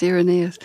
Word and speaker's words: Irenaeus: Yes, Irenaeus.